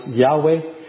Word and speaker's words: Yahweh: Sometimes pronounced Yahweh, sometimes pronounced in the older versions Jehovah.